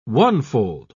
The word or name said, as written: onefold